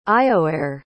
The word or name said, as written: iomhair